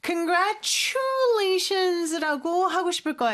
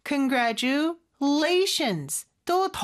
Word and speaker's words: congratulations: Congratulations 라고 했는데 Congratulations 라고 하고 싶을 거예요. 근데 요 TU가 합쳐지면 추 발음이 나잖아요. 근데 여기는 T 발음보다는 그냥 지읒 발음처럼 해주는 경우가 많습니다. 그리고 gra 요 부분에 강세가 있어요. Congratulations 또 더 강한 강세가 또 뒤에 있죠.